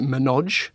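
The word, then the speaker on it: ménage: It's written menage, the French word. They pronounce it menage.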